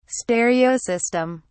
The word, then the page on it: stereo system: British English